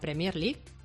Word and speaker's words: Premier: La Premier League. – Pero es que ¿no? se acentúa todas las sílabas. ¿Cuál es? ¿La primera o la tercera? – La primera.